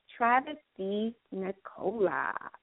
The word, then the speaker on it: DiNicola: So let's go ahead and welcome in our featured guest, Executive Director of Indy Reads, Travis DiNicola.